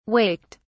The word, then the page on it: waked: British English